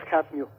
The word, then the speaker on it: Hafnio: Creo que sí se pronuncia Jafnio, que no debería, pero pues se, quedó la, pronunciación como si fuera inglés.